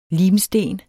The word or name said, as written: limsten